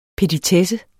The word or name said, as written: petitesse